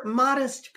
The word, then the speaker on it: Modist: First, that's how you pronounce the name because the brewery is spelled M O D I S T, but modest.